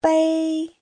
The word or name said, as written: bei1